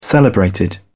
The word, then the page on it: celebrated: British English